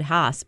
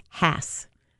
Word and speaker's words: Hass: I know, I always said Haas, but it's pronounced Hass after the guy.